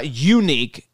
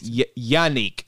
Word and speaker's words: Yannick: It's not unique. It's Yannick.